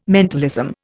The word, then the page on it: mentalism: Indian